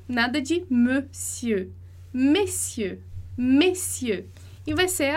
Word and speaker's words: monsieur: Então, o monsieur vai ser ali, me, né, fechado, monsieur. Eu vou até tirar esse e aqui para você perceber a diferença.